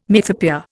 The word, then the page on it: mythopoeia: Indian